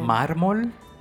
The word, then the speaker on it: mármol: It's mármol. I had it on my head. – Mármol? – Mármol.